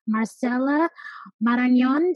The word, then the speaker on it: Marcela Marañon: Today, we have a very special guest, Marcela Marañon.